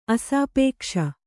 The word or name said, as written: ಅಸಾಪೇಕ್ಷ